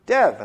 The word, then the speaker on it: Dev: So that's why after a while I stopped correcting people when they saw D-E-V and they pronounced it Dev.